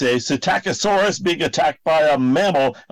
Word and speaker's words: Psittacosaurus: You can say it. Psittacosaurus. – Psittacosaurus. – It's a silent P.